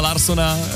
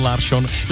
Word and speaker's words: Larsson: Takže my jsme Persson a Larsson celé ty roky říkali špatně. Správně je to Persson, Larsson.